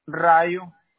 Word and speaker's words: Rayo: Rayo. – Could you spell that, please? – R-A-Y-O.